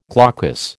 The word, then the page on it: glaucous: American English